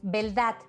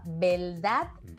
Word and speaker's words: beldad: Beldad. Y no me he equivocado al pronunciar, ¿eh? Quiera decir verdad con V y se me haya aturullado la lengua. Beldad. Con B.